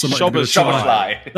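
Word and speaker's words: Szoboszlai: Shoboslai.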